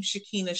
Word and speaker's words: Shakina: So on LinkedIn, my handle, I would call it, is my name, Shakina Shaw. So that's spelled S-H-A-K-I-N-A, last name S-H-A-W.